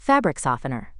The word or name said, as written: fabric softener